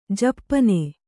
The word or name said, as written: ಜಪ್ಪನೆ